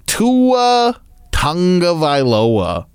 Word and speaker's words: Tua Tagovailoa: When we come back, we're going to hear from Dolphins quarterback Tua Tagovailoa.